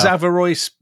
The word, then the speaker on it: Averroes: Is that Averroes? Yes. Am I saying that right? – Yeah.